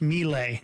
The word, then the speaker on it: Milei: I believe it's pronounced Milei.